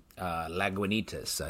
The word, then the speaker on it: Lagunitas: Lagunitas.